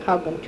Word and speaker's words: Haugen: But we had a lot of snow at Camp Haugen, too. About the same as we do here in Michigan, the lights in Michigan. – How do you spell that camp? – Camp, H-A-U-G-E-N, Haugen.